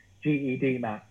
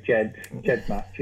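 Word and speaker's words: GEDmatch: So most things are correct, but like she said, GED match, and I said, "No, you just need to say Jed match."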